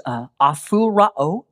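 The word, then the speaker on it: aphorao: It's pronounced aphurao. Aphurao is the Greek word.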